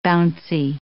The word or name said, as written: bouncy